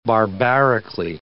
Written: barbarically

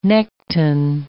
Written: nekton

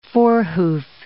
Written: forehoof

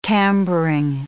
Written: cambering

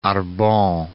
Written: Arbon

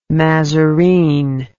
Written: mazarine

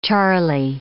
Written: Charley

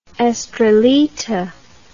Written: estrellita

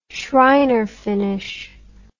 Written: schreiner finish

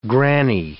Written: grannie

